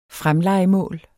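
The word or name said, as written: fremlejemål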